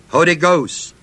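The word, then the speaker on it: hodigos (ὁδηγός): The word for guide is the Greek word hodigos, H-O-D, long E, G-O-S.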